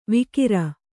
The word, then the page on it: ವಿಕಿರ: vikira